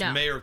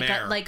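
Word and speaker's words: Mare: It's M-A-R-E. Mayor. Not mayor.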